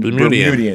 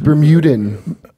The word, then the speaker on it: Bermudian: You pronounce that, Bermudian? – Bermudian. – Because that's been bothering me for a long time. – Really? – I thought it was Bermudan. – No, that's that. Yeah, that's not.